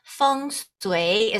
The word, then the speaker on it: feng shui: So feng shui is how you say it. And feng, which is what you call F-E-N-G, feng, is the wind.